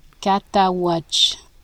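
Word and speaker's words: Cataouatche: And somewhere between the Mississippi River and Lake, how do you pronounce that? Lake Catawatch. – Catawatch, okay. It's French.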